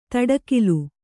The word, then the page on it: ದಾಟುಗಾಲು: kannada